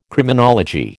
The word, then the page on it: Criminology: American English